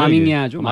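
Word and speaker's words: maminha: 찾아보니까 이게 어브라질로로 이제 브라질로로 이게 뭐라고 읽어야 되나? 마밍야죠. 마밍야. 마밍야. – 마밍야.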